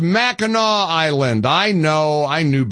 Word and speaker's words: Mackinac: Of course, it's spelled Mackinac, and I was saying Mackinac like a dummy. And my text line has blown up, and people are calling poor Alex. And I know it's Mackinac Island. I apologize. I pronounced it the way it is spelled. That's a strange deviation of the spelling, isn't it? It's Mackinac Island, not Mackinac.